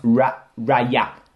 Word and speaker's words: Raya: It's pronounced Raya.